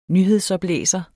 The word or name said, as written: nyhedsoplæser